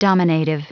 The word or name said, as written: dominative